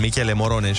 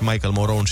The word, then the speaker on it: Michele Morone: Apropo de treaba asta, eu nu știu dacă tu o știi cu Olex care nu știe să pronunțe chestii, gen Michele Morone și Michael Moron și ce mai greșea el acum o oră.